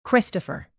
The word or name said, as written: christopher